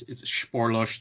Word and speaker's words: Spoorloos: Yeah, I looked it up on Google one day. I'm like, I have to know how to pronounce this damn movie. And so it's Spoorloos.